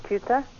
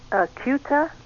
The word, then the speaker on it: Kuita: My name is pronounced Kuita. – Say your last name again. – Kuita. – Kuita, okay. So the I is silent.